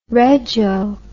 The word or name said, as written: rego